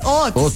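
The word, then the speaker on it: haute: Es que es H-A-U-T. ¿Eso no es francés? Hot. Sí, eso es francés. Pues en el colegio me enseñaron que era francés, sí. – Hot. Sí, hot.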